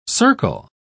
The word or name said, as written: circle